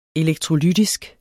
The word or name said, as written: elektrolytisk